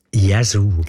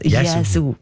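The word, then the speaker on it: Yazoo: Ich habe mich immer gefragt, ob ich das richtig ausspreche oder nicht. «Jesu». – Jesu. – Gell? Jesu. Ja. Richtig.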